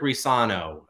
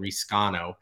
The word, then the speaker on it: Riscano: Two more guys, Kedrick Risano. The C is silent, just for fans out there that are saying Riscano, who I think we actually have a little underranked.